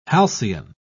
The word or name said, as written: halcyon